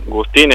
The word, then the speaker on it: Gustín: Juan Gustín es mi apellido. – Gustín, con acento en ahí.